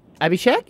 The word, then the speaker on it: Abhishek: It's pronounced Abhishek. – A B-shake. – A B-shake. – A B-shake. – The sound the B-H makes does not exist in English language, so nobody can say it right. A native English speaker cannot say it right because it's pronounced a E shape.